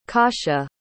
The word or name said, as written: kasia